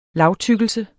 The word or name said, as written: lagtykkelse